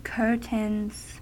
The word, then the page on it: curtains: American English